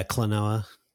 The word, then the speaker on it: Klonoa: What is this? Klonoa? Is that how you say it? Yeah, Klonoa.